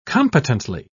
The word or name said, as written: competently